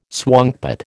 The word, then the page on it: swankpot: American English